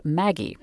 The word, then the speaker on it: Maggi: Not just Bovril and Liebig's extract of meat, but also another one that's really popular today called Maggie. At least that's how we're going to pronounce it. There are some places in the world where it's pronounced Maggi.